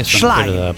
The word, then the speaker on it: Schlein: Schlein. Non è difficilissimo, anche se sì, non sappiamo il tedesco. – È un vaso di Pandora sta cosa. Stai attento. Schlein. Cominci a correggere la pronuncia.